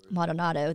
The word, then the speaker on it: Maldonado: And then he had Baskins, I can't even pronounce this woman, Madonaldo, somebody. Maldonado, that's Joe.